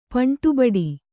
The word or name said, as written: ಪಂಟುಬಡಿ